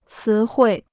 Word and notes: 词汇: cí huì

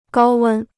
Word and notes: 高温: gāo wēn

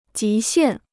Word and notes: 极限: jí xiàn

